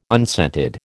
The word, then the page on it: unscented: American English